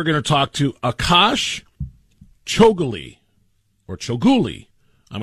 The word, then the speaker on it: Chougule: Chougule is what I'm going with as I look at the pronunciation guide. Akash Chougule is what I'm going to run with.